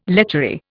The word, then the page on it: lechery: Indian